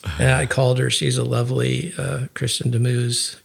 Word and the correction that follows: Du Mez: Kristen Demuse from uh, maybe. sorry, is the way I think you pronounce it Du Mez.